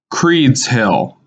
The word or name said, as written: Creeds Hill